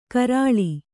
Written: ಕೆರಳ್

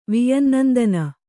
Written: ವಿಯನ್ನಂದನ

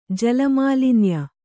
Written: ಜಲಮಾಲಿನ್ಯ

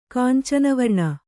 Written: ಕಾಂಚನವರ್ಣ

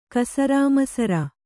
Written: ಕಸರಾಮಸರ

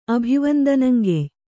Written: ಅಭಿವಂದನಂಗೆಯ್

